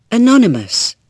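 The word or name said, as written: anonymous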